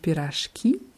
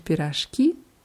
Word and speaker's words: пирожки: где можно купить пирожки, in Plural ist die Betonung auf dem letzten i, где можно купить пирожки, где можно купить пирожки.